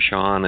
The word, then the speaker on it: Sean: Sean, it's spelled the correct way, S-E-A-N, last name M-U-Z-Y-D-L-A.